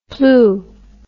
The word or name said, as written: pleugh